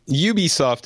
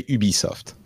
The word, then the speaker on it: Ubisoft: Ubisoft is fine if you're speaking in English. In French, we say Ubisoft.